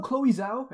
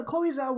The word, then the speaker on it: Chloe Zhao: So you know Chloe Zhao? Am I pronouncing that right? – Yes, I believe so.